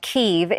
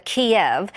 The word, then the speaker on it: Kyiv: If you're wondering why the name of the Ukrainian capital is being pronounced Kyiv instead of Kiev, it's because Kyiv is the Ukrainian way of pronouncing it, while Kiev is the Russian.